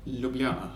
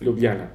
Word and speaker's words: Ljubljana: Am I saying that right? Ljubljana, I think. Ljubljana. – Is the English pronunciation.